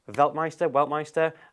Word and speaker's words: Weltmeister: Weltmeister, is that how we're pronouncing it? Weltmeister.